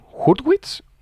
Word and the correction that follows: Hurwitz: Eh, creo que es Hurwitz, sí. – Hurwitz, Hartwitz o, Justin, Bueno, se escribe H-U-R-W-I-T-Z. Justin Hurwitz, por así decirlo.